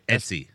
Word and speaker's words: Etsy: Ecstasy. You can get it off Ecstasy. I think that's how you pronounce it, right? – Etsy.